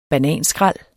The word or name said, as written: Bananer